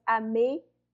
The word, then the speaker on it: Amélie: You put like an A after the M. That's the first thing people do, because there's like an accent on the E, so it's Amélie. – So, Amélie.